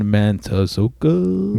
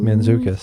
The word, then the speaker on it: Mantzoukas: Jason Mantzoukas. – Mantzoukas.